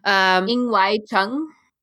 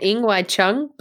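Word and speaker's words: Ng Wai Chung: Ing Wai Chung. – Ing? – Ing. – Okay, NG is just Ing. Okay, then Ing Wai Chung, Blitzchung.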